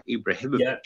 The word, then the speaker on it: Ibrahimovic: Did I say that right? Ibrahimovic. – Yeah.